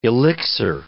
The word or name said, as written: elixir